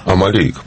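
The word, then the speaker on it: Амальрик: Я правильно ударение ставлю? – Амалейко.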